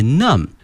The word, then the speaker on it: numb: 首先看一下我们的舌头口腔，这个 tongue, T-O-N-G-U-E，我们讲过后面这个发麻这个词很好玩，它的原型呢是 N-U-M-B numb， numb，注意一下后面这个 B 啊是不发音的哈， numb 表示身体的一个部位发麻了，比如说我们坐的时间太长了，我们的腿会感觉到 numb，如果说我们的一个手拿东西拿的太久了，也会感觉到这个 numb。